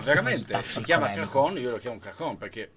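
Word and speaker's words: cajón: Si, chiama, si pronuncia cajon.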